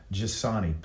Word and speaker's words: Giussani: This time we have Chad Giussani.